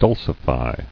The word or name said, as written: Dulcification